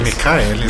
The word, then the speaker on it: Michaelis: É, eu estou aqui lendo a definição de orgulho, segundo o dicionário Michaelis ou Machelis, eu nunca sei como pronunciar. Acho que é Michaelis.